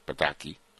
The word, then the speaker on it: Pataky: Oh, Pataky, there's no S in there. Pataky. That's how you pronounce it, right?